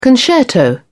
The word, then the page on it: concerto: British English